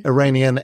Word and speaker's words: Iranian: So you're Iranian, is that how you say it? – Iranian and Kiwi.